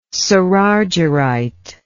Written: cerargyrite

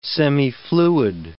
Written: semifluid

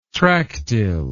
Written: tractile

